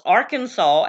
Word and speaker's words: Arkansas: um, you must pronounce Arkansas as Arkansas. Apparently, Arkansans are offended, maybe, by it being called anything else other than the correct pronunciation.